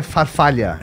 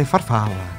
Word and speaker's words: farfalla: Ah, então não é farfalha. – É farfalla. – Farfalla. – Farfalla. – Farfalla. – Tem que fazer a mãozinha, faz a mãozinha. Farfalla. – Agora a pronúncia tá certinha.